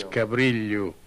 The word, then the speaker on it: Cabrilho: His name pronounced in Portuguese is João Rodrigues Cabrilho. – Cabrilho. – Cabrilho. That's a sound that's similar to the sound in million. Lhe. That's Cabrilho.